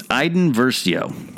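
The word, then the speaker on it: Iden Versio: It's Aiden Versio. – Mispronunciation is part of Star Wars. – Aiden Versio. V-E-R-S-I-O. – Oh, Aiden, Versio. – I-D-E-N.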